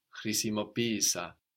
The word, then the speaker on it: χρησιμοποίησα: But because we are treating these two E's like two separate syllables, the first one takes the accent and we get χρησιμοποίησα. So actually it feels like we have the accent second from the end, no? It's only because Greek sees these two E sounds as two syllables.